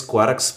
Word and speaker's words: quark: La palabra quark se supone que debe pronunciarse como quart, cuarco, pero con una K al final en vez de una T, pero normalmente se pronuncia de manera que rima con lark, o sea, jerga.